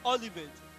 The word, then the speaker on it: Olivet: Can we pronounce that mount? Olivet. I was about pronouncing Olive. I don't know if it's the same thing. Or King James is giving us something different. Okay. Mount Olivet.